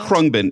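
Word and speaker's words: Khruangbin: Krungbin.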